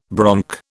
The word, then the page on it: bronc: British English